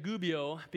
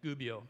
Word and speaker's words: Gubbio: And I said Gubbio because I looked up how to pronounce it online. And there was an Italian guy saying Gubbio.